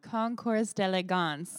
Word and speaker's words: Concours d'Elegance: Concours d'Elegance.